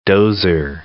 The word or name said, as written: dozer